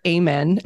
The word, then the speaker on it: Amen: I want to share, I was listening to a podcast by Dr. Amen. Is that how you say it? Daniel Amen. – Like amen to that? Yes.